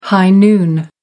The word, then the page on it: high noon: American English